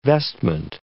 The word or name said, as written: vestment